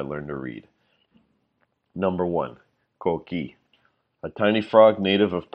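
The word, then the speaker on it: coquí: The word coqui is an excellent example because it also teaches us the pronunciation of the combination K, ki, that is also like a K in English.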